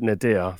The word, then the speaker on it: nadir: The absolute nadir, that's the right pronunciation of the word, was a Newcastle game.